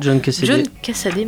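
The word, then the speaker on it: John Cassaday: Alors le dessin, il est de monsieur, attendez que je vais bien prononcer son nom, John Cassady,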